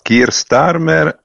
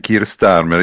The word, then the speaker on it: Keir Starmer: Innanzitutto ci chiedevamo, un po' ridendo in redazione, come si pronuncia correttamente il nome di Keir Starmer. – No, Keir Starmer è Keir Starmer,